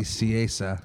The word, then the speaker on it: Nicieza: He was created by Fabian Nicieza. – That's his name. I looked up how to pronounce it, and you said it exactly. – Really? – Yeah. – I never looked it up. – Nicieza, yeah.